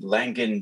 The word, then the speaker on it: Langen: Correct me if I'm saying it wrong. – No, that's good. You're the first person that has said that kind of on point. So word up. Langen. How Langen. We say it in Germany.